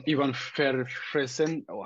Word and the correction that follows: Fresneda: Fresneda, right?